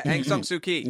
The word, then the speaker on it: Aung San Suu Kyi: Aung San Suu Kyi.